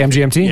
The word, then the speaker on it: MGMT: I think it's management, isn't it? MGMT? Isn't that how you pronounce it? I always assumed it was management. – No, you don't pronounce it management. – You just say MGMT? – Yeah.